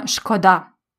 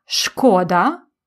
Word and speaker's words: шкода: Here you can say шкода or шкода. Шкода, it is how the dictionary says to pronounce it, but шкода is how many people actually pronounce it.